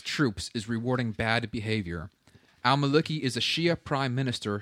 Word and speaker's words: al-Maliki: He goes on to say, to give al-Maliki... I don't even know how to pronounce that. Al-Maliki? – That's about right.